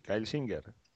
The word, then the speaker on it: Singer: ¿Cómo se pronuncia? ¿Cómo lo has dicho tú antes? – Sí, sí, Kyle Singer. – Kyle Singer, ¿no? – Singer, cantante.